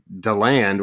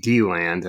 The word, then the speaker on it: DeLand: I got into an argument with Avante Williams, Rivals 100 defensive back, about how to say DeLand, where he's from, where he insisted it's pronounced DeLand. And I was like, that's not how it's pronounced.